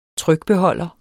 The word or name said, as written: trykbeholder